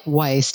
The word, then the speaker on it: Weis: which is spelled like Weiss, W-E-I-S, but it's pronounced Weiss, which is a German-made wine.